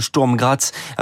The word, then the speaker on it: Sturm Graz: Lyon et Monaco déjà qualifiés ont tous deux fait match nul 1-1 respectivement contre le Glasgow Rangers et le Sturm Graz.